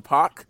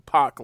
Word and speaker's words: Pac: Of course, Kenny Omega and Hangman defeated Pac and Moxley after Moxley turns on Pac, or Pac, excuse me, I keep doing that. Because, like, P-A-C is Tupac, so you pronounce it Pac, not Pac, you know, it's kind of weird. And then, but I have to think, P-A-C is also Pac like Pac-Man, so he's Pac like Pac-Man, not Pac like Pac like Tupac.